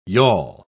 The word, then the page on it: yawl: English; British English